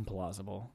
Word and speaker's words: implausible: It's mm-hmm. implausible, not implausible. – Did I say implausible? – You said unplausible. It's pronounced, it's implausible.